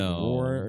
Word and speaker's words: Roar: It was like Roar or some shit. She said, you said, really? You said War or some shit. – Roar. I can't pronounce my R's.